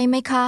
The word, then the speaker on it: ไหมคะ: คุณเข้าใจภาษาไทยไหมคะไหมคะ The end of that question is pronounced with two high-level tones.